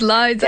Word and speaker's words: Liza: They can't just say Liza. They're like Lisa, Liza, I don't, Lily. It's Liza.